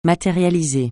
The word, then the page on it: matérialiser: francais